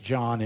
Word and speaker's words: Jean: I think they pronounce it John. It's the French, but you won't care today.